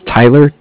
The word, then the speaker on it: Tyler: My name is Tyler, T-Y-L-E-R. – Uh-huh. – And I'm calling with Cardiff. It's spelled C-A-R-D-I-F-F, as in Frank.